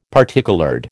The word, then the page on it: particoloured: American English